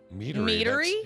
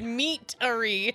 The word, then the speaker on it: meadery: Meadery? – Meadery? – M-E-A-D. – Mead. We both heard meatery. – That's a meadery. Isn't that how you say it? – I mean, meadery is a thing, too.